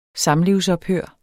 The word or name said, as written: samlivsophør